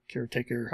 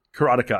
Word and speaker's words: Karateka: When I think of a game like this on the Apple II, my mind goes to Karateka or Caretaker, however you pronounce it. – I pronounce it properly, Karateka. I don't care what the guy who made it says. – Yes. – That's the way it was pronounced.